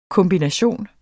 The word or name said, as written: kombination